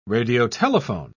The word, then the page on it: radiotelephone: British English